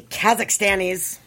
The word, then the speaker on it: Kazakhstan: Well, those crazy Kazakhstanis.